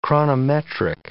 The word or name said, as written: chronometric